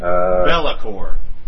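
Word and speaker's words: Be'lakor: I'm not sure how to say their name. I've been calling them Buckhorn. It's B-E-apostrophe-L-A-K-O-R. – It's like Bellacore. – Bellacore, I don't know.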